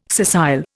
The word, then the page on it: scissile: Indian